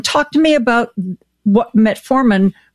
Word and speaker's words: metformin: I've got to ask you, you know, one of the things that I've read about is the use of, I don't use metformin.